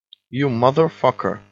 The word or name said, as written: you motherfucker